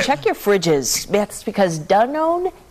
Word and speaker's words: Danone: Danone. That's the way it's pronounced. – I was always saying Dannon.